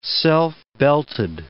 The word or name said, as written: self-belted